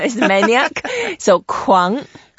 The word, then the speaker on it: 狂: Okay, let's learn how to say it first. Kuang. – Kuang, which tone? – Second tone. – Second tone. Kuang.